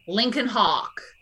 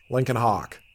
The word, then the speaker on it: Lincoln Hawk: Lincoln Hawk. – Lincoln Hawk.